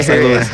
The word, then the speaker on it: St. Louis: Nah, bro, St. Louis. – You heard me say. You heard me pronounce the S.